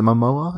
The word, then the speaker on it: Momoa: Is it Momoa? Is that how you pronounce his name? – Yeah, yes.